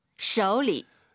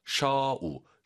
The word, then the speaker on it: 手里: 그리고 쇼가 아니라 오유는 어우입니다. 쇼우 쇼리 꼭 기억하시고요. 쇼쇼 안 돼요.